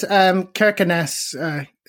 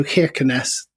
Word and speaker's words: Kirkenes: Yes, Kirkenes. Interestingly, the people there seemed to turn the first K into a sort of a sound a bit like a Russian H. So Kirkenes, many people seem to say, but...